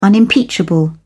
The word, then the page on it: unimpeachable: British English